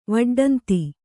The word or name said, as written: ವಡ್ಡಂತಿ